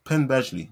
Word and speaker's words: Penn Badgley: Penn Badgley.